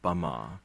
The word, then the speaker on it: Myanmar: Myanmar in colloquial Burmese is pronounced Bama, which was then changed by the Portuguese and the French and the British when they arrived a few hundred years ago into Burma.